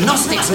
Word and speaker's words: Gnostics: The Gnostics with a silent G, the G's with a silent Gnostic.